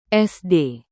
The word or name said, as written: sd